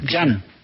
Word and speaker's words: Jean: Bueno, sí, pues se escribe J-E-A-N. J-E-A-N. Yo le digo Jan. O si quiere le dicen Jen. O Jen. Jen Colorado. Pero yo he oído que dicen Jan.